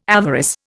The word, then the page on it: avarice: Indian